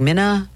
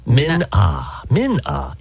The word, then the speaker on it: Min Ah: I didn't know how to say her name. I was like, Minna? – Min Ah.